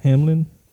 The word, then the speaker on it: Hamlin: Hamlin.